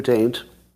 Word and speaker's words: Tate: And I know some of you think that I'm pronouncing it with an N, but that's just a Yanni and Laurel thing. You hear it that way because I primed you. But I'm actually saying T-A-T-E.